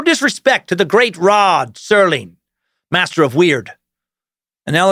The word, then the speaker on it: Serling: Sci-fi sucker David Thornberry writes, It's Rod Serling, not Sterling.